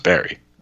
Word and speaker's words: Barre: Okay, so it's Barre. – Okay. – So you have that in Pennsylvania, right? How's it pronounced there? – It's Wilkes-Barre.